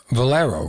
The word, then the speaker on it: Valero: Volvero. I forgot how to say it. Like Valero, right? – Yeah, Valero.